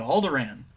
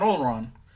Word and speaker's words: Alderaan: But we, you know, he's like, you must come with me to Alderaan. Not Alderaan. – Alderaan.